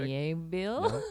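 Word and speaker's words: Miéville: Mieville.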